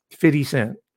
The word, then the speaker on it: 50 Cent: It says 50 Cent, but we pronounce it 50. We drop the F. That's part of the Ebonics, so I'll say 50, 50 Cent.